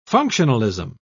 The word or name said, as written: functionalism